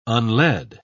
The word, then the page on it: unled: British English